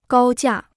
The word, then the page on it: 高价: gāo jià